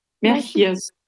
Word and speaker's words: Merchiers: Ja, und der Name wird dann ausgesprochen? – Merchiers. Ja, wie Sie wollen. Ja. – Merchier oder Merchier? – Ja, geht's.